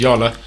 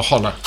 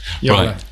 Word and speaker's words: Jolla: Chat to the guys at Yola, which I've now learnt how to pronounce is Yola, not Jolla or Holla. – Yola.